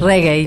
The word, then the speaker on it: reggae: Si alguien, como pasó después, tocaba un rigue olvídate, eso no es no es amigo nuestro. y después reggae. Ok. Y después, y después, porque Pamela me corrige. Pues, ¿Cómo se dice bien? Reggae. Eh, reggae.